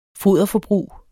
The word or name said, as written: foderforbrug